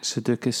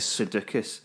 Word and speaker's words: Sudeikis: Sudeikis. – Sudeikis.